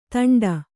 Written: ತುಂಟ

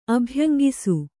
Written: ಅಭ್ಯಂಗಿಸು

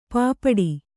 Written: ಪೈಪಾಡು

